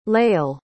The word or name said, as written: layal